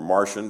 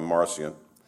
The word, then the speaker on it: Marcion: And around 144, this bishop, his name was Marcion, or Martian, depending on how you want to pronounce it, but we'll call him Marcion, formulated a theology that rejected the Hebrew God, the one that is in what we call the Old Testament.